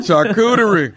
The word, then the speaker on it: charcuterie: That charcuterie.